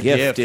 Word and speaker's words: GIF: Well, some people call it a gif, yeah, but I call it a gift with a hard G. – You received a gif. What is it? It's gif, though. – Well depending on now the Will Height, the programmer who invented the file format, believes that it's gif. – Okay. – I happen to like the hard G because we don't say gigabyte, we say gigabyte. – Right. – And I like a hard G before a short vowel.